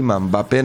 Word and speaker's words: Mbappé: ¿Te acuerdas que le habíamos preguntado a Juanma Figueredo cómo lo que se pronuncia realmente? ¿Es Mbappé o es Mbappé directamente? Pero es Mbappé nomás.